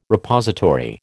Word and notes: Repository: American English

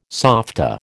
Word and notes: softa: American English

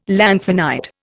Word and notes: lanthanide: Indian

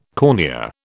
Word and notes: cornea: British English

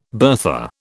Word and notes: bertha: British English

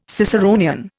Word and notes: Ciceronian: Indian

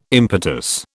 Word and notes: impetus: British English

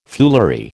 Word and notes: foolery: American English

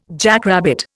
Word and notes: jackrabbit: Indian

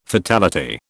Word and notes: fatality: British English

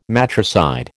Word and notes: matricide: American English

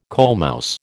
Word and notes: coalmouse: American English

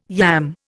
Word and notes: yam: Indian

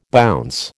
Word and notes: bounce: American English

